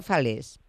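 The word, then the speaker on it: Falaises: Comenzáis hoy, decían, en los teatros del canal con este espectáculo que yo pronunciaba fatal, pero que me dicen que se pronuncia Falés. – Es Falés, si no me equivoco, es acantilado en francés.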